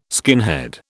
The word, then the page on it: skinhead: British English